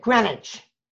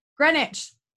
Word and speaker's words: Greenwich: And by the way, just pronounce it Greenwich. – Greenwich.